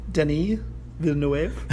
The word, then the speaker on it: Denis Villeneuve: Denis Villeneuve or something like that? – Denis Villeneuve? Yeah. You gotta throw a little French on there and you'd be fine.